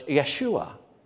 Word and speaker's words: Yeshua: So when the angel said the name that we have in the text, Jesus, it would have been pronounced, because there's no J sound in Hebrew, so it's Yeshua.